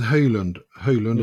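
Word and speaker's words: Højlund: His name is Höylund, as I pronounce it, but he's a highly touted player.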